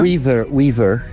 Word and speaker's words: Weaver: Dream Weaver, si je le prononce bien. – Weaver.